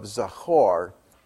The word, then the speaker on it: Zachor: Zachor. Zachor, you can spell that Z-A-C-H-O-R, is the rehearsal, the recitation, the remembrance of the sacred moment, which translates the prayer, the one praying, into the moment itself.